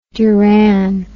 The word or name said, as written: duran